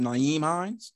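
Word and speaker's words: Nyheim Hines: And then we have – is his name Naeem Hines? Said it right.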